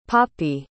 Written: poppie

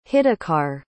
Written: hitakar

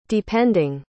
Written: depending

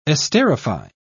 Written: esterify

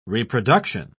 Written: reproduction

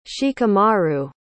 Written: shikamaru

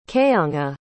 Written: kayonga

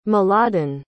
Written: mladen

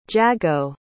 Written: jago

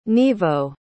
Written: nivo

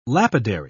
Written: lapidary

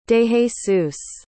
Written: dejesus

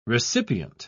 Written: recipient

